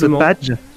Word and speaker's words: PADG: Et ça se prononce badge.